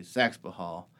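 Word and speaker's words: Saxapahaw: It's Saxapahal how it's spelled, but the locals down there say Saxapahal.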